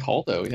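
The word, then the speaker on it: Holdo: How do you pronounce it? Haldo? – I Haldo? – think Haldo, yeah.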